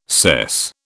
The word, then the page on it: sess: British English